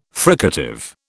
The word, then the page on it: fricative: British English